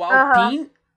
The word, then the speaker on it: Alpine: É o Alpine e o E praticamente você não pronuncia. Você meio que para no N, entendeu? Alpine e aí acabou.